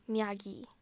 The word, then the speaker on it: Miyagi: Miyagi. Sorry. – No, you were saying it right.